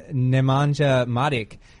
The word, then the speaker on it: Nemanja Matic: In addition to Fabregas, they also have Branislav Ivanovic and Nemanja Matic.